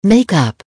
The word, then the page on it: make up: American English